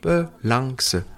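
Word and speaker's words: pe lang se (프랑스): Le R se prononce comme un L et le son en, qui n'existe pas, se transforme en son A, à qui on ajoute une consonne finale, G, mais très douce, comme dans ping, prononcé avec l'accent du midi. Et eh, donne-moi du pain. Et donc le rang » va se prononcer en lang. Et enfin, la dernière syllabe de France est prononcée pleinement comme dans ceci ou cela. Et donc nous arrivons à peu lang se.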